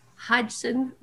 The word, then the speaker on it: Hodgson: The Secret Garden, 1911, by Frances Hodgkins Burnett. I can't ever say her name. Frances Hodgson Burnett.